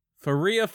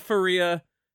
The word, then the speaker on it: Faria: We were saying it wrong the whole time. – Hmm. – I just listened to them saying their name. – Right. – And it is Faria. Because they said, Faria, Faria, you remind me of a West Side Story growing up in Spanish Harlem.